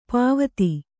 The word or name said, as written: ಪಾವತಿ